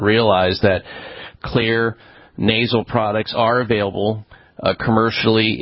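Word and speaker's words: Xlear: Put in there that the reason it's pronounced Clear is because X in Finnish, which is where they did most of the leading research on xylitol, is pronounced like a soft K.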